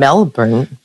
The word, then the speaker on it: Melbourne: I am proud to be your first virtual guest, but I'm also very sad not to be in Melbourne, Australia. Did I say that right? – You did.